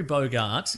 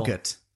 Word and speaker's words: Bogart: And it's Humphrey Bogart. – Yeah. – And somebody else who I can't recall. – Boggart. – It's not pronounced Boggart.